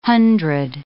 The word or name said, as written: hundred